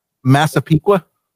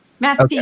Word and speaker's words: Massapequa: Massapequa. – Yeah. Massapequa.